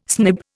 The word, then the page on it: snib: Indian